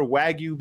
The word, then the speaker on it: wagyu: Did I say that right? Wagyu. – No, you did not. Wagyu. I did not. Okay. It's like wagyu.